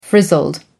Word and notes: frizzled: American English

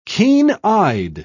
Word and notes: keen-eyed: British English

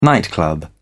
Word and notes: nightclub: British English

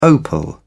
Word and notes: opal: British English

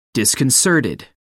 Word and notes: disconcerted: American English

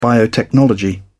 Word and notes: biotechnology: British English

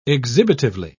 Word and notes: exhibitively: British English

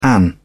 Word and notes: an: British English

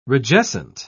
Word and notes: rigescent: British English

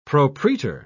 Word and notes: propraetor: British English

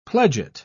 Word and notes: pledget: British English

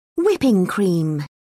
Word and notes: whipping cream: British English

